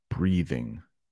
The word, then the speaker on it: breathing: Breathing, there's a little TH in there. Breathing, breeding. Very soft D, breeding.